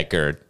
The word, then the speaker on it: GERD: Yeah, GERD.